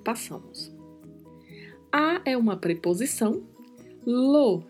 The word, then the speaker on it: lo: E a pronúncia é lo mesmo, como se você tivesse um acento circunflexo em cima da letra O. É um som bem mais fechado, lo.